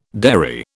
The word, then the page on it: derry: British English